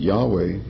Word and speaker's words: Yahweh: But we recognize that Yahweh, and most scholars recognize Yahweh as a more correct pronunciation.